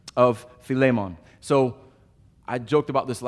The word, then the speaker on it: Philemon: I've heard Philemon my whole life. So I've been repeating over and over and over again the Greek pronunciation, Philemon, Philemon.